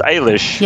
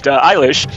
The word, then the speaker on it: Eilish: Eilish, I believe it's pronounced Eilish. – Yes. – Not to be confused with Billy, but Eilish, from a band from Los Angeles, a rock blues alternative band with two sisters.